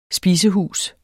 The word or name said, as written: spisehus